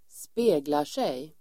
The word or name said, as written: speglar sig